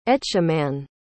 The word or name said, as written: etchemin